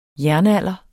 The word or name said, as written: jernalder